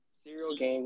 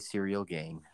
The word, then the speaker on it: Cyril Gane: Cyril Gane? Cyril Gane. What's Cyril like? – Okay, Cyril Gane. Sounds better.